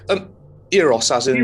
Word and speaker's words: Euros: Uh, I believe the correct pronunciation for this is actually Eros.